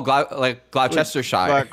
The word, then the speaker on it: Gloucestershire: Oh, Gloucestershire. – Gloucestershire. – Gloucestershire. – Yep. – Gloucestershire. Yes.